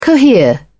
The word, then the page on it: cohere: British English